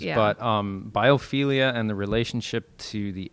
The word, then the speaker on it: biophilia: Biophilia.